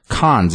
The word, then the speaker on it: Cannes: This comes after the announcement that his film, The French Dispatch, will premiere this next week at the Cannes Film Festival. – I think it's Cannes. – I said, well, he did. – Cannes. It's C-A-N-N-E-S. – I think the S is silent.